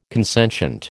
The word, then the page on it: consentient: American English